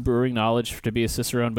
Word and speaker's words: Cicerone: It's pronounced Cicerone.